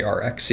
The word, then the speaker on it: RX: I'm, I'm, uh, do they pronounce it just RX or do they say prescription? – I think they say RX.